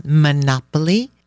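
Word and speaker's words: Monopoli: called Monopoly, like the board game, but spelled with an I at the end.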